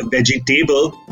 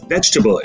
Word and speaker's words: vegetable: So that's something that really irks me, and I'm just itching to correct them and tell them it's not veggie table, but vegetable. It's not comfortable, it's comfortable. I think the reason people make this pronunciation boo-boo is because they tend to pronounce every syllable the way it's spelled.